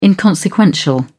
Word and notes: inconsequential: British English

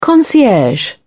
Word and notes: concierge: British English

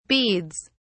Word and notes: beads: British English

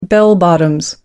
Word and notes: bell bottoms: American English

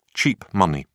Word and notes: cheap money: British English

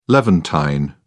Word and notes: levantine: British English

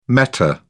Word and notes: meta: British English

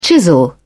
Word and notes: chisel: British English

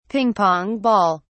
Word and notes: ping-pong ball: British English

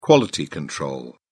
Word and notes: quality control: British English

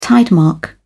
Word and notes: tidemark: British English

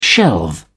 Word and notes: shelve: British English

